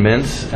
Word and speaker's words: Mintz: My last name is spelled E-M-B-E-R-S-I-T. – Dave Caperton, Federal Reserve Board, C-A-P-E-R-T-O-N. – Stephen Mintz, M-I-N-T-Z, staff of the FCIC.